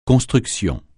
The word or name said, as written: construction